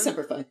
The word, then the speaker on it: Semper Fi: Semper Fi! – Yeah. Yeah. I was waiting for that. – I'm probably saying that wrong. – Yeah. – It is Semper Fi.